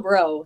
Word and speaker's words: Braud: So my dad is from Baton Rouge, Louisiana, and the A-U-D is another version of the E-A-U-X. So it is, you don't pronounce the D, and it's like, you know, how they do the G-E-A-U-X for LSU, like go Tigers. That's where I'm at. So it's Kayla Bro. Like, what's up, bro? – Do you think it could possibly be cooler, though, with the E-A-U-X on the end of it? – Okay, yes.